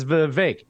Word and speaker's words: Vivek: Uh, my name's Vivek. It rhymes with cake.